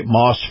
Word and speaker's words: Maus: I want to remind everybody that this portion of the program is brought to you by Moss Family Automotive. Moss is spelled M-A-U-S, and their Acura dealership here in Tampa on North Florida Avenue.